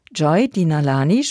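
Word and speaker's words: Joy Denalane: Joy Dinalani spricht man so, Joy Dinalani.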